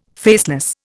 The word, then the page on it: faceless: Indian